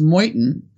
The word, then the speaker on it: Meuthen: I was saying Meuthen. It's very hard with some of these German names. His is particularly weird, and it's pronounced Meuthen.